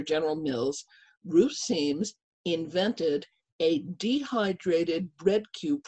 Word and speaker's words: Siems: One of my favorites, and it's totally appropriate for this season, is a humble home economist from Indiana named Ruth Seams. Her name is spelled S-I-E-M-S.